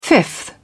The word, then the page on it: fifth: British English